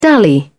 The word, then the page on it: dally: British English